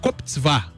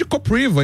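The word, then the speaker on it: Kopřiva: Porque, por exemplo, tem um tenista que é o Koptsvá, mas se escreve Kopriva. Então todo mundo chamava ele de Kopriva e tal. Aí ele não, é Koptsvá, porque em tcheco, né, se fala assim.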